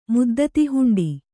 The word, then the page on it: ಮುದ್ದತಿಹುಂಡಿ: kannada